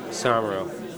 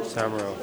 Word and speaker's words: Somero: Somero. – Somero? – Somero, yeah. – How do you spell that? – S-O-M-E-R-O.